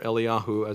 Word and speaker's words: Eliyahu: Elijah, or Eliyahu, as it says in the text, the Hebrew way of pronouncing his name.